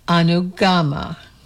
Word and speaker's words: Anugama: The correct pronunciation is to put the emphasis on the third syllable, Anugama.